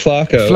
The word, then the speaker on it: Flaco: If I pronounced it right, it is Flaco the owl. – No, actually, it's Flaco.